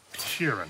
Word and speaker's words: Sheeran: Frank Sheeran.